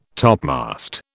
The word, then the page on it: topmast: British English